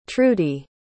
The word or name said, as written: trudy